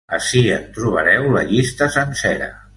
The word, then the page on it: sencera: Catalan